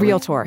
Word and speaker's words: realtor: It's realtor, not realtor. – Realtor. – Two syllables, not three.